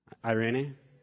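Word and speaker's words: Irene (εἰρήνη): From the Greek word Irene, I'm probably saying that wrong too, right, Peter? Irene? Thank you.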